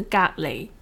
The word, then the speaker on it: 隔離: 嗯，隔离的广东话的发音是隔离。